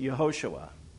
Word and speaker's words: Yehoshua: When it's pronounced Yeshua, not meaning a name like our Savior, but when it's Yeshua, as in the word for salvation throughout the Old Testament, it doesn't have the same pronunciation. It's Yeshua versus Yehoshua, but it has the exact same spelling.